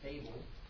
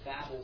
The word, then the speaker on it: Babel: But the correct pronunciation is actually Babel, and it's really a Hebrew verb.